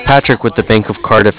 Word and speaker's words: Cardiff: Yeah, it's with Cardiff. – With Curtis? – With Cardiff. – Can you spell that for me, please? – Yeah, I know it sounds weird. It's C-A-R-D-I-F-F, like Cardiff, Wales.